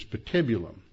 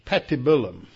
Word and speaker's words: patibulum: Now, the English pronunciation is patibulum. Then this morning I found a website that actually pronounced the Latin terms, and it was patibulum.